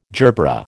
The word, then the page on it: gerbera: American English